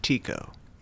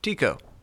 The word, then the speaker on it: Tycho: It's pronounced Tico. – Hmm? – Tico.